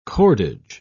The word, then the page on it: cordage: British English